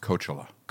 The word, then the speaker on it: Coachella: It's pronounced Coachella.